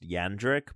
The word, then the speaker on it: Jandrick: Pretty sure his last name is pronounced Jandrick, but that's cool. – Now that's not what he said when he helped me with that tire. – Oh yeah, he was like, it's a hard J sound.